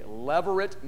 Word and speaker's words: levirate: Let me spell that for you. It's L-E-V-I-R-A-T-E, levirate marriage. Now, when you hear L-E-V-I, you may immediately think, oh, that has something to do with Levi. Nope, it has nothing to do with Levi. It comes from the Latin word lever, L-E-V-I-R, which means husband's brother.